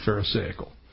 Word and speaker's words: pharisaical: We call it being pharisaical. Or pharisaical, if you want it actually pronounced correctly. I like pharisaical because it sounds better. I don't know why. But it is actually pharisaical.